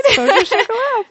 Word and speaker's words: Vosges au Chocolat: We are at the Vosges au Chocolat. Did I pronounce that right? Vosges au Chocolat. Perfect.